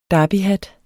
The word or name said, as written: derbyhat